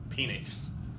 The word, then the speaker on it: Penix: Indiana's QB's named Penix. – Penis. – Rhymes with penis.